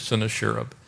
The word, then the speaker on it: Sennacherib: And so that Sennacherib, or Sennacherib, whichever way you want to pronounce it, I believe it is Sennacherib, king of Assyria, came up against all the fortified cities of Judah and took them.